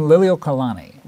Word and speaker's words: Liliuokalani: Queen Liliuokalani.